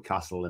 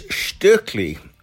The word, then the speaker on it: Stöckli: It's like Kessler skis and some people call it Castle and, you know. – Yeah, well, actually sent us through a little clip on how to pronounce it properly, so let's have a listen to that. – Hi. It is Stöckli, because obviously it's a Swiss company.